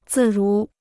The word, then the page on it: 自如: zì rú